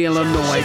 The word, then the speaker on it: Illinois: Kelly Arts Records out of Chicago, Illinois. I used to call it Illinois. Heard it once in a movie. And then I always used it, but it's actually Illinois. Don't pronounce the S.